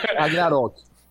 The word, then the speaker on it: Ragnarok: O, o K sai com G, mas é velho. Ragnarok.